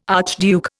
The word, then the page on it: archduke: Indian